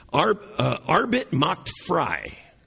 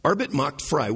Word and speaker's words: Arbeit macht frei: And it is a German phrase that is pronounced Arbeit macht frei. Arbeit macht frei, which means in English, work will set you free.